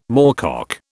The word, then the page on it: moorcock: British English